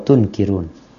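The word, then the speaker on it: Tunkirun (تنكرون): Fata'rifuna wa tunkirun. – Yang mana ya Ustaz? Fata'rifuna wa tankiruna. – Tunkirun, pakai dhamma ya. Tunkirun. – Innahu yusta'malu alaihum umara'u fata'rifuna wa tankiruna. – Ini tankirun, ini dibacanya tunkirun.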